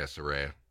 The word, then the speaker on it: Saraya: Saraya.